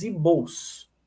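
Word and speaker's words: Igbos: Tem esse G aqui, mas esse G fica mudo na pronúncia, os Ibos, tá?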